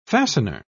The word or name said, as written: fastener